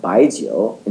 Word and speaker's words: Byejoe: We called it Baijiu, B-Y-E-J-O-E, so that any Joe in America could pronounce it.